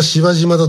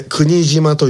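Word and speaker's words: 柴島: みんな芝島だと呼んでしまいます。阪急千里線の駅であります。これ国島と読みます。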